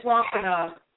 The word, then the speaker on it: Wampanoag: Actually, and matter of fact, the name of the tribe you want to try and find is the Wampanoag, Wampanoag tribe of the, I can't pronounce that. – It's Wampanoag.